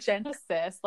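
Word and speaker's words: Genasis: It's spelled Genesis, like with an A. So whenever I look at it, I just thought it was Genesis.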